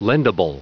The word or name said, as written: lendable